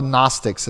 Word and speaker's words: Gnostics: The other group that they were dealing with in the early church were called the Gnostics. I don't know if you're how familiar you are with the Gnostics. It's not spelled with an N, it's spelled with a G. G-N-O-S-T-I-C-S.